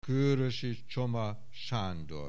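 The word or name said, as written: Kőrösi Csoma Sándor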